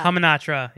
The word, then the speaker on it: Hamunaptra: Hamunaptra. – Hamunaptra, yep. – Hamana. – Hamunaptra, come, Hamunaptra.